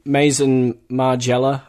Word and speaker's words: Maison Margiela: I got some Maison Margiela, I think she's called.